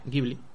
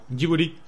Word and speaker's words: Ghibli: que no se pronuncia Ghibli, ¿vale? Eh, se pronuncia algo así como Jiburi. porque la traducción japonesa, el Ghibli viene de un viento italiano y eh, él, lo cogió, cogió ese nombre, lo modificó a su un poco a su gusto y en, japonés se pronuncia así.